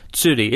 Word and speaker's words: tsuri: Tsuri. It's spelled like T-S-U-R-I, tsuri.